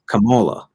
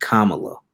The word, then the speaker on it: Kamala: Like I'm like it's not that, it's Kamala, not Kamala.